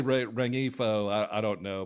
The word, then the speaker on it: Rengifo: So I really like Luis Renfro. If you need... – You're talking about Luis Rengifo, right? Is that what you're saying? – Rengifo, okay. Yes. I do not pronounce the G.